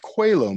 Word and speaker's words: Coelho: And that became it's pronounced Coelho Medallion. And it's spelled Coelho, but it's named for Paulo Coelho, the author of The Alchemist.